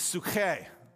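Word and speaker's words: psuche (ψυχή): But the Greek word for soul here in our text is psuche. I like to put that phlegm in there, you know, the Greek. But it's spelled psyche.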